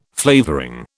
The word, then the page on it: flavouring: British English